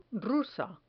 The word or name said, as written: rusa